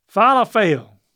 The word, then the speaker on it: falafel: Yeah, emphasize the middle syllable. Falafel. That's really close. Falafel. Falafel. Falafel. It's like, how you feeling today? – Hmm, falafel? That's how I remember it. I feel awful.